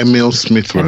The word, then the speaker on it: Emile Smith Rowe: Is that am I saying his name right? Emile Emile. – Yeah, eh, Emile Smith Rowe.